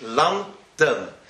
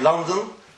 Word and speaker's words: London: If you see, how do you say London in English? London. It's a pasuk and it's a segel. It's not London. It's not. But both of them, when they write, they don't. Even though in English you write two N's, two O's, L-O-N-D-O-N, but the first O is more of a passive O, and the second O is more of a second O. That's the proper, how you say it in English.